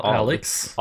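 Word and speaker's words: Alyx: Half-Life Alux, Alux, jeg vet ikke hvordan du skal uttale det. Alix. – Al Alex. Alex. – Alex. Alex. Det er Alex, ja.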